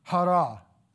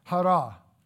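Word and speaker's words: hara (χαρά): The word for joy is the word hara. It's spelled C-H-A-R-A, but you pronounce it hara.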